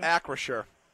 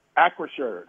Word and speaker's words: Acrisure: Acrisure. – Acrisure, the Acrisure Stadium, which was Heinz Field at the time when I was there.